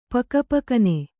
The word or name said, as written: ಪುಕಪುಕನೆ